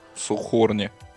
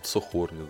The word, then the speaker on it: Saucony: Ну, это не New Balance были, это Saucony, или как это читается, блядь, не знаю. – Saucony. – Saucony, да.